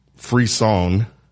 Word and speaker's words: frisson: Like so the word of the week is free song.